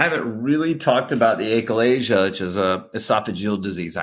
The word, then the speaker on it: achalasia: And then also, you know, the, if I say this correctly, the achalasia, is that right? – Yeah. Right.